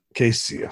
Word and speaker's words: Cayce: One of the people that you also mentioned in your book was Edgar Cayce. Casey. So I always mispronounce things.